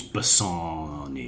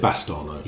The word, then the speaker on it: Bassano: Anyway, I would just like to say, on the Shakespeare pod, a subject very close to my heart, I mistakenly pronounced the name of the Dark Lady, supposedly... and I still can't remember how it's. I think it's Bassano. Yeah. – You said Bastano.